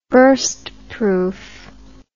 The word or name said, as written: burstproof